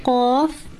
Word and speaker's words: qaf: Qaf is pronounced from here. When the extreme back portion of the tongue touches the soft palate above.